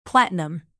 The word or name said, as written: platinum